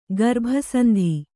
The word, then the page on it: ಗರ್ಭಸಂಧಿ: garbha sandhi